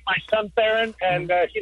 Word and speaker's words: Theron: Theron. – Theron like Charlize. – Exactly. Hmm. And pronounced like Theron.